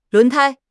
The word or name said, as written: 轮胎